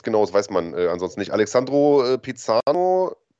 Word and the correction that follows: Pisano: Pisano, ja, habe ich richtig gesagt, äh, fragt, wie ist es erfahrungsgemäß mit Karten für London?